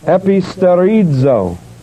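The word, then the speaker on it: episterizo: The word confirm is Epistarizo, E-P-I, S-T, long E, R-I-Z, long O, E-P-I, S-T, long E, R-I-Z-O, Epistarizo, and that means to establish more solidly, to confirm or establish more solidly.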